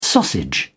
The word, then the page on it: sausage: British English